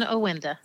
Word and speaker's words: Awinda: Jen Awinda.